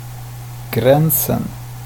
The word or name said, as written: gränsen